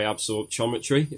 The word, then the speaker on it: absorptiometry: So that's dual energy X-ray absorptiometry, if I said that right. – Brilliant. I'm glad you did.